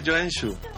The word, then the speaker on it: Joensuu: Se pronuncia Joensuu, no Joensuu como diría un españolito.